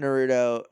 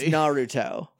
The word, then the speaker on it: Naruto: At one point, I knew enough about Naruto, and I'm saying Naruto the way that I used to say it to make my little brother mad. – Is that not how you say it? I'm sorry if I offended anybody. – It's Naruto. – Oh. – Because of the way syllables line up with each other.